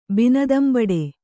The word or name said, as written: ಬಿನದಂಬಡೆ